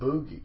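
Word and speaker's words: Booji: Somebody yelled out Boogie. And he got pissed. He goes up to the mic. He's, we do not. – Classic. – Which I heard was the real pronunciation of Bougie Boy.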